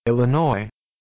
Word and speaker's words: Illinois: Illinois, please don't pronounce the S.